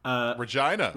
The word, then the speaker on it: Regina: I believe it's Regina or something like that in Canada. – Regina. – Yeah. That's what I thought it was, but I didn't want to. – Yeah, but you don't want to say that because it rhymes.